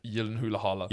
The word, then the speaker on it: Gyllenhaal: All know that the actual pronunciation is Jake Gyllenhaal. I believe it's Norwegian.